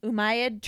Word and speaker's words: Umayyad: The Islamic or Umayyad troops... How you say it? – Umayyad troops.